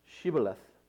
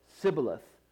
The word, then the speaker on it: Shibboleth: And if he said no, then they would say to him, then say, now I'm going to pronounce it, first of all, the English translation, Shibboleth. And they would say, Shibboleth, for he could not pronounce it right. And, they would say Shibboleth, and they would say Sibboleth, for he could not pronounce it right.